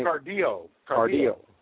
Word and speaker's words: Cardillo: Cardillo.